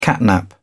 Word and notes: catnap: British English

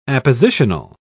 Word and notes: appositional: British English

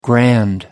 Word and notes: grand: American English

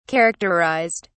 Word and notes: characterised: British English

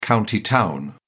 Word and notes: county town: British English

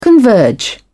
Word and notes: converge: British English

